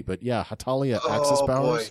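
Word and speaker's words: Hetalia: Heshalia Axis Powers? – Oh, boy. – Am I reading that correctly? – Hatalia.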